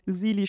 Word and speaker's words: Zealy: Und Sili schreibt man mit Z, also Z-E-A-L-Y, für die Leute, die es auch vielleicht mal googeln wollen.